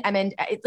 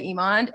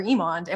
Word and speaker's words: Emond: And E-M-O-N-D, hidden, like, watch the whole episode for Catherine's last name, is E-M-O-N-D. But people say Edmund, Emmond, like, you would be so surprised how many ways you could say Emond, and it's never Emond.